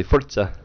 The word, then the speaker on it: Forza: Or if you want to pronounce it correctly, Forza.